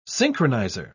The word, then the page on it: synchronizer: British English